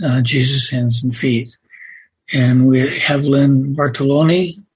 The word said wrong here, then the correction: Bartlotti: Is that how you say it, Len? – Bartolotti. – Bartolotti, yeah, with us.